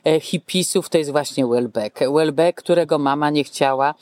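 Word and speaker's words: Houellebecq: Ale ponieważ, jak sądzę, nie wszystkim naszym słuchaczom nawet postać Welbecka może się nawet nie zorientowali, o kim mówimy, bo tak to wymawiamy ładnie po francusku.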